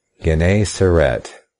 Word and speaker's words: Gennesaret: Gennesaret. That's the pronunciation I get from the Greek there. And that's what they give us in the authorized King James Version.